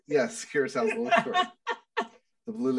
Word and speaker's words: Kurosawa: Akira Kurosawa? Did I say that right? – Kurosawa. I think is what you were trying to say.